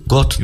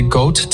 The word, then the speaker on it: goat: Şimdi Sezer'in söylediği telaffuzu duymadan birebir ne dediğini bilemem ama İngilizce'de goat tek hece G-O-U-T gibi söyleniyor. Goat. Yani goat gibi Türkçe yazsan goat.